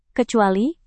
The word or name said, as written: kecuali